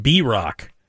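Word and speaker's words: Bierock: It's pronounced B-Rock, but best set in the style of an old German man attempting to clear his throat.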